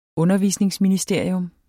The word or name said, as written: undervisningsministeriet